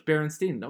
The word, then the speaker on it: Berenstain: Berenstain, okay. Turns out Berenstain is spelled S-T-A-I-N.